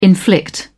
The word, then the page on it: inflict: British English